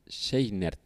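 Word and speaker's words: Scheinert: Es Dan Juan y Daniel Schneider. Seinert, Perdón por la pronunciación.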